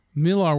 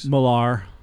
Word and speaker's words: Millar: And then the other thing is Miller World, or however the fuck you want to pronounce it, got purchased up. Miller World Comics. – Millar.